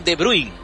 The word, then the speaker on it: De Bruyne: Kevin De Bruyne, como se pronuncia originalmente, Jeremy Doku y Charles de Ketaler. Este es el 4-2-3-1 para enfrentar a Senegal.